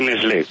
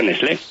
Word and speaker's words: Nestlé: era la Nestlé. Entonces yo no sé por qué, pero la T efectivamente no la pronunciamos.